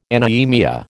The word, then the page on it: anaemia: American English